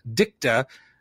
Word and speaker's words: dicta: It was that judge's dicta.